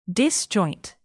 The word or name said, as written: disjoint